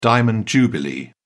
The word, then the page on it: diamond jubilee: British English